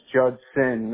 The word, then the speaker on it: Judson: It's Judson, not Judston. There's no T in the name.